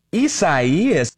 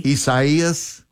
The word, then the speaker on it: Isaias: This is the way to say it. – Isaias. – Say it with me, everybody. – Isaias. – Isaias. – Right. That is the correct way to say it.